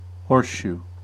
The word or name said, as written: horseshoe